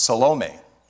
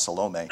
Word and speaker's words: Salome: You can figure out that Mary's sister was Salome. And if you want to say Salome, that's fine. I'm trying to pronounce it the way that I think it was originally pronounced, Salome.